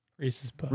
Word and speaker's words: Reese's Puffs: So, Reese Puffs? Reese's Puffs? How do you say it? I don't know. – I think it's Reese's. – It's Reese's, and you keep saying it weird.